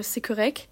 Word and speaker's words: correct: bah il y a la fameuse expression c'est correct où ils prononcent pas le T, mais ça c'est pour dire euh, ok à peu près, oui, ou c'est très bien, ils l'ont réduit en c'est correct.